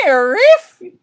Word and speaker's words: NIIRYF: Those are the letters for Not If I Reboot You First, and they're pronounced NIRIF!